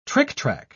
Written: trictrac

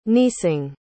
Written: neesing

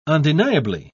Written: undeniably

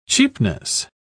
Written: cheapness

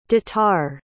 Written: detar

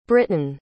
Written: britten

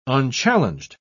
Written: unchallenged

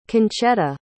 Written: conchetta

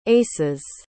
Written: aces

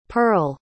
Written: pearle

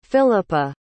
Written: Phillipa

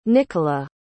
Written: nikola

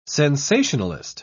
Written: sensationalist

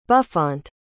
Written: buffont

